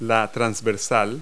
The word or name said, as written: yoke